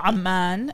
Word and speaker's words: Amman: Oh, Amman is how you pronounce it in Arabic.